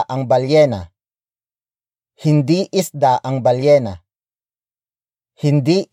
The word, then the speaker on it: balyena: Ang is spelled as A-N-G. It is the focus marker, which introduces the main noun of the sentence, and that is balyena. Balyena. Balyena. Balyena is spelled as B-A-L-Y-E-N-A. It means whale.